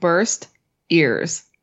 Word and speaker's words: Burr Steers: B-U-R-R, as in Aaron Burr. – Okay. – Steers, as in cows. – You said it, and it sounds like burst ears.